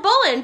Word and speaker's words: Bolin: So I tell him Bolin, B-O-L-I-N.